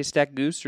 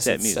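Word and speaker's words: StatMuse: Did you say Stat Goose or did you say Stat Muse? – I said Stat Muse, but Stat Muse. – Muse. – I don't know. – Muse, not Goose.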